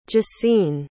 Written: gesine

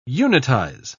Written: unitize

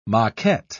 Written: maquette

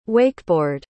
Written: wakeboard

